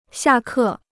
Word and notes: 下课: xià kè

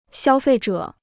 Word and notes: 消费者: xiāo fèi zhě

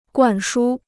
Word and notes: 灌输: guàn shū

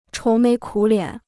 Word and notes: 愁眉苦脸: chóu méi kǔ liǎn

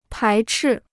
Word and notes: 排斥: pái chì

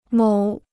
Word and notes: 某: mǒu

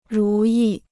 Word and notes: 如意: rú yì

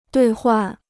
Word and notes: 兑换: duì huàn

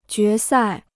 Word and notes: 决赛: jué sài

